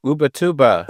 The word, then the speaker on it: Ubatuba: Brazil, Ubatuba. Am I saying that right? – Ubatuba.